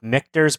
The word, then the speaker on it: Michter's: Well, originally I was going to go with Michter's bourbon because Michter's rhymes with Richter, which is her last name.